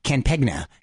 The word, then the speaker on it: campaign: Campaign. – Yeah. – You don't say the G. Campegna.